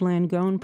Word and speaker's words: Langone: And again, I'm here in Philadelphia and not New York City, but I want to make sure that I have Langone pronounced correctly.